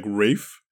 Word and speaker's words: Ralph: Like, I think pronouncing the word itself is like Rafe.